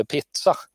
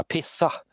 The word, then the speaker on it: pizza: Jag vet ju, Moa Martinsson har ju ett radioreportage från tidigt 60-tal ondgjort sig över pizza, som hon då uttalar pissa.